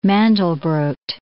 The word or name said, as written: Mandelbrot